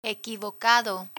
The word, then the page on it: equivocado: Spanish